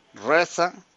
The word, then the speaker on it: RZA: Reza, porque así se pronuncia, RZA.